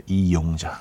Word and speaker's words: 이용자: 음, 많은 분들이 이영자 씨 이름으로 들으셨네요. 이용자였습니다. 이용자. 예. 발음 조심해야지. 이용자.